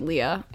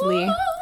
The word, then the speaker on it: Lea: It's Corinna underscore Lee, L-E-A. Not to be confused with Leah. As a child, I wanted it. – I wanted it to be pronounced Leah as a child, but my mom always said it's Lee.